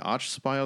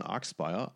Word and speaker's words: Archspire: Wahrscheinlich ist es nicht Archspire, sondern Arkspire aus Vancouver, British Columbia in Kanada.